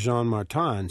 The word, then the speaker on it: Jean-Martin: And to look at it in one way, you can say his first name is actually spelled Jean, as in J-E-A-N, and then a hyphen, and Martin, although the pronunciation is Jean-Martin, so it's Jean hyphen Martin, if you want to look at it that way.